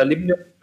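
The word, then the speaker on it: Limnios: Limnius oder Limnius, wie er ausgesprochen wird, hat jetzt sein Debüt als eingesetzter Spieler gefeiert, hat gleich mal ordentlich Druck gemacht.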